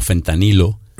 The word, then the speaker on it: fentanilo: Perdón, hacemos una aclaración porque por la pronunciación aquí es que lo está más conocido como fentanilo.